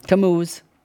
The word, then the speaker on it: Tammuz: And they were weeping for Tammuz. Did I say it right? Tammuz or Tammuz? Tammuz.